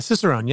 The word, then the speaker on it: Cicerone: Oh, and more importantly, certified Cicerone. Did I say that right? Uh, Cicerone, yeah.